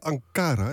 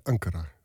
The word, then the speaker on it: Ankara: Bijvoorbeeld over de lange arm uit Ankara. – Lange arm uit Ankara. Mooi, zei je dat? Moeilijk woord. Dus het allemaal binnenrijmen. – Je kolom zei je Ankara. Het is volgens mij Ankara.